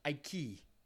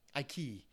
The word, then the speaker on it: Ike: But you got to pronounce his name right. It's pronounced Ike. Ike.